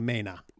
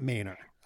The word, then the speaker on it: Mainer: Now, we must say, though, you are truly raising a Maina. That's how you say it here. – Am I? – Yeah, that's how you say it here in Maine. So when you're raising a Maina. It's not Mainer.